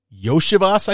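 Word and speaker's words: Iosivas: Andre Yoshivas, I think is how you pronounce that, if I'm not mistaken.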